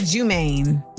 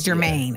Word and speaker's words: Jermaine: No, he said his name wrong. He said a different name. – Oh. He said Jumaine. – He kind of like J-U-M-A-I-N-E. Oh, he said James. Okay, it's Jermaine.